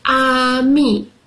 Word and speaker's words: ami: You say it like this, ami.